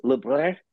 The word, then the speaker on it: Lebrun: Okay, so technically it's Lebrun, which is the actual French pronunciation, but I've anglicized it just to make it easier for everybody.